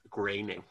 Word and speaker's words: Groening: It's pronounced Groening.